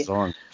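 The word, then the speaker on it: DAZN: É, eu acho que empresas como a, a DASNE, que é assim que se pronuncia, d a, é DASNO, enfim, já ouvi várias formas, agora eu esqueci como que era a forma é, que é, pronuncia. DASON. Chama. – DASON, é isso, DASON, exato, DASON.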